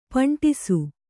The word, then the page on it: ಪಂಠಿಸು: paṇṭhisu; Kannada